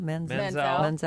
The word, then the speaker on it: Manziel: Manziel.